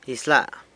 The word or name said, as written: Izyla